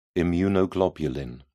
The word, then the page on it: immunoglobulin: British English